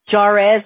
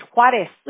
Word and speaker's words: Juárez: If you would hear my teachers cómo pronunciaban mi nombre, Juárez decían, porque como la J es... como no es silent, right? No yeah. pronuncias como J y ellos siempre, ay Dios mío. Juárez me decían así. Y yeah. lo escribían con un W y decían, no, es con J. es Juárez.